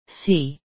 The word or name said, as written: see